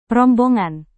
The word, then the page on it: rombongan: indonesian